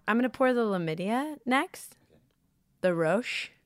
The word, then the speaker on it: Lammidia: If you haven't seen it, it's Lamidia. Rhymes with chlamydia.